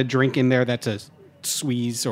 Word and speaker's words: Suze: Suze. – Oh, Suze. – Yeah, yes. – S-U-Z-E.